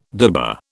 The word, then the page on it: dibber: British English